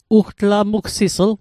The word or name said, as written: ugtlamugsisl